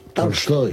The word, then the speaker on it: Tolstoy: No es exactamente Tolstoy, es Tolstoy. Tolstoy. – ¿Cómo? Lo dije. – Tolstoy con A, una. – Embajador. Tolstoy. Lo dije Tolstoy. Así tal cual. Tolstoy.